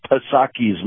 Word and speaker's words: Psaki: They just don't want to because it would screw up the narrative coming out of Jen Psaki's mouth. – Yeah, our circle back girl. – Yeah. Yeah. And I'm pronouncing the P because I think making that silent is oppressive.